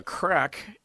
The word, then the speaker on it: craic: That's how it's pronounced, right? Just like a crack in the granite or a crack in the armor or exactly. – Crack in your behind or whatever it happens to be. But it's spelled C-R-A-I-C.